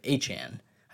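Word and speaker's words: Achane: Actually, a couple for this week, um, but likely getting Devon A. Chain um, and then Justin Jefferson back for next week for week 11 sorry, Achan. I knew I said that wrong.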